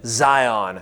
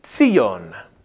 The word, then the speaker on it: Zion: This actually pronounced with a TS at the beginning Zion. Zion. We don't pronounce many Hebrews words right.